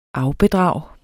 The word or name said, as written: afbedrag